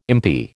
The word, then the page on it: impi: American English